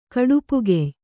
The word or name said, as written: ಕಡುಪುಗೆಯ್